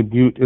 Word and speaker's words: Butte: And in the Gold Butte, by the way, Butte is the proper pronunciation of that.